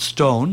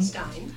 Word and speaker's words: Stein: Ich spreche sie mal Deutsch auf Gertrude Stein. Gertrude Stein, nein, sag mal, Gertrude Stone, nein, wie würdest du das sagen? – Stein.